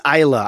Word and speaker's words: Isla: It's Isla. I had to look it up because I've mispronounced it for apparently over a decade. Okay, it's Isla Fisher.